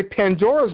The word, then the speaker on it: Pandora: the notion of a Pandora's box, and what indeed is a, and it's pronounced in Greek, Pandora. Okay, that's how you pronounce it.